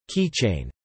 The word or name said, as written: Keychain